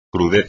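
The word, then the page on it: crudeza: español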